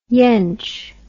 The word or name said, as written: yench